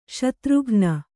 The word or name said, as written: ಶತ್ರುಘ್ನ